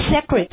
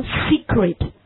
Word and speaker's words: secret: 它不读 secret, 它读 secret, secret, secret. 好，那么我们的重音不一样，元音也不一样。